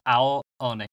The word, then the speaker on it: Ao Oni: Yep, Aoni.